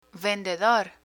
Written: vendedor

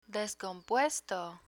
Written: descompuesto

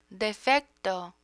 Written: defecto

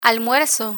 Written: almuerzo

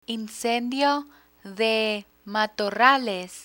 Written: incendio de matorrales

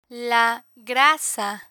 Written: la grasa

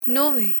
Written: nube

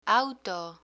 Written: auto

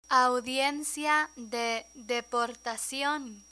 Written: audiencia de deportacion

